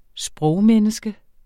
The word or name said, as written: sprogmenneske